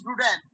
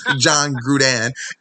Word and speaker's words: Gruden: I think it's pronounced Gruden. – Okay. John Gruden.